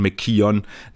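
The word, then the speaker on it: McKeown: Nee, Kaylee McKeon, Entschuldigung, Kaylee McKeon, beide sehr ähnlich geschrieben, Emma McKean mit K-E-O-N und Kaylee McKeon, K-E-O-W-N. Auch hier mal schön die wirklich native australische Aussprache zu hören, dann kriegt man auch mal mit, dass dass die Emma, die 100 Delfin-Schwimmerin, eher McKean mit Doppel-I quasi gesprochen wird und McKeon noch so ein kleines O mit drin hat.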